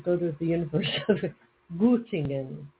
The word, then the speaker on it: Göttingen: Göttingen is how it's pronounced. I mean, it's how it's the IPA says.